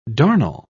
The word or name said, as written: darnel